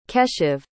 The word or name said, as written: keshav